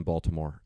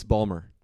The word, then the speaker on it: Baltimore: She lived in Baltimore and I was there for a couple days. – You said it incorrectly. It's Balmore. – Balmer? – I don't know. – It's Balmer.